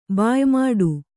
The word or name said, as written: ಬಾಯಿ ಮಾಡು